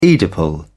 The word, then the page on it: oedipal: British English